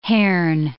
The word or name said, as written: hern